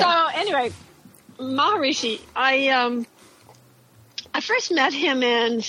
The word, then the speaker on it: Maharishi: How'd you get with Mr. Maharishi, his second name? I can't pronounce. – No, you pronounced it correctly. Maharishi.